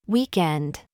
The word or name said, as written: DAD